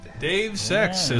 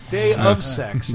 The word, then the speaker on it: Deus Ex: Dave Sex, as we accidentally pronounced it. Yeah. Dave Sex, which is still, like the review score from us, undetermined. – Day of Sex is, I think, the preferred pronunciation.